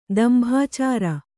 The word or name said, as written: ದಂಭಾಚಾರ